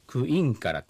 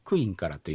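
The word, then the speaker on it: クインカラ: サル方言以外ならですね、クインからって、インのところにアクセントが、来る方言が多いんですけど、サル方言の場合はアクセントが移動しちゃってですね、クの上に来ちゃうと。だからクインからというような言い方になります。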